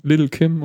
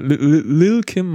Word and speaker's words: Lil Kim: Es ist Little Kim oder Lil Kim. Man darf ja das hinten nicht aussprechen.